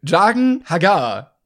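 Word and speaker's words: Jaqen H'ghar: Jagen Hagar. Spricht man, glaube ich, aus.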